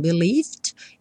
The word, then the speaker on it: believed: O believe, eu não pronuncio E, então believed.